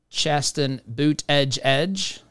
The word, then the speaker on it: Buttigieg: Chasten Buttigieg, not to mispronounce the name there, as we have been lectured quite extensively by the media and the radical left. Chasten Buttigieg, that is Mayor Pete's husband.